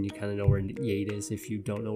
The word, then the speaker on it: Yate: I think that's how you say it, Yate. Like, yeet.